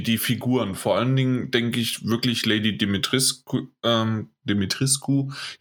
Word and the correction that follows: Dimitrescu: Jetzt muss ich mich wieder dran gewöhnen, dass es eigentlich richtig ausgesprochen wird. – Kannst du äh, Dimitrescu sagen?